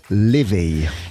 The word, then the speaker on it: Laufey: la jeune chanteuse islandaise Leveille Oui, ça s'écrit Lofey et donc on a eu confirmation en entendant attribuer son Grammy cette nuit, que c'est bien l'éveil.